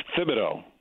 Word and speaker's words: Thibodeau: It's Thibodeau. – Thibodeau.